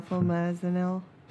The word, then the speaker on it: flumazenil: Yeah, flumazenil.